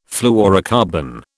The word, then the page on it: fluorocarbon: British English